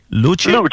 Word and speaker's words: Luce: Aussi, tu voulais nous jaser de la Ferrari Luce. C'est-tu comme ça qu'on la prononce? – Luce. – Oh, Luce. OK, je sors mon italien, là.